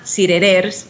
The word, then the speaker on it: cirerers: En realidad se llama cirerers, como el árbol, pero la última r no se pronuncia,